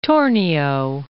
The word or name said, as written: Tornio